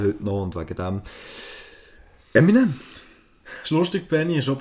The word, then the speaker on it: Eminem: M&M. Eminem. Marshall Mathers. Mhm. Viel schneller ausgesprochen Eminem.